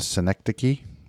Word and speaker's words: synecdoche: So I ran across today in my Bible study, you'll know this word, the synecdoche.